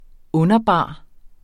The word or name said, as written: underbar